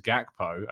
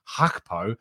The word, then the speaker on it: Gakpo: I am told under pretty good authority that you do actually pronounce the G, and it is Gakpo. I see a lot of people pronouncing it just Hakpo, but I'm told that it is genuinely you pronounce it with a G.